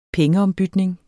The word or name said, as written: pengeombytning